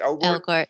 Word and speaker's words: Elgort: I like the cast, with the exception, I'm sorry, I'm Ansel Elgort, how do you pronounce it? – Elgort, yeah.